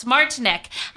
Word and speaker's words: Martineck: Martineck.